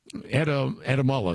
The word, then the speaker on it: Adamala: Kate Adelama. Adamala, excuse me, I guess how she pronounces her name, is a synthetic biologist professor at the University of Minnesota.